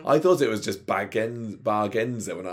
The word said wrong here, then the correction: Bargainanza: I was really thinking about like Sheldon off the Big Bang Theory with his Bazinga. But yeah, Bargainanza, this is called.